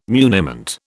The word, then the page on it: muniment: British English